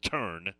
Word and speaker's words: Thurn: And it's spelled T-H-U-R-N, but it's pronounced Turn, took over.